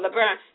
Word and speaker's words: LeBron: Oh, LeBron.